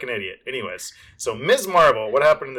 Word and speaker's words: Ms. Marvel: So first off, it's Ms. Marvel. I don't know why, but I kept saying Miss Marvel. But it's not Miss. It's Ms. because it's M-S dot, M-S period, which is pronounced Ms. Just, I want to clarify that because I was calling it the wrong thing all this time.